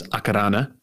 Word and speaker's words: Akarana: Akarana, actually appropriately pronounced as Akarana, is a golf club in Auckland.